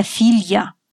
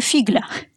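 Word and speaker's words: figlia: Да-да-да, дочка – это филья. А то я слышала версию фигля.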